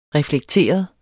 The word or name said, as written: reflekteret